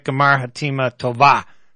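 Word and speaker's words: G'mar Chatimah Tovah: So I believe in maybe Richard Silverstein, my guest, can tell me if I'm pronouncing this right. Gemar Hatima Tova. Did I get that right? – Mm-hmm. – All right. – You got it pretty close. – Yeah. – 99%.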